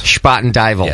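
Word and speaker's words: Spuyten Duyvil: Spoiten Duyvel.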